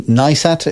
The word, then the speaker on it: Neistat: Casey Neistat, is that how you pronounce his surname? The American guy? – Yes. Keep going.